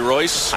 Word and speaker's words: Reuss: Jerry Royce. – I don't know. I'm not familiar with them. – Spelled R-E-U-S-S.